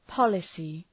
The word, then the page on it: policy: ‘pɒləsı